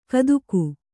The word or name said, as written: ಕದಿಕ